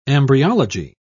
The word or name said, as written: embryology